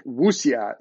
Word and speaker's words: wuxia: I believe Wuxia is the way that it's pronounced in Chinese, and that's the term in Chinese for sort of like.